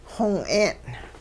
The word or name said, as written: xong-'een'